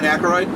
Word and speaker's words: Aykroyd: Ray Stantz, played by Dan Aykroyd. – I love the way you say Aykroyd. – Why is that? Am I saying it wrong? – It's like you're adding a syllable.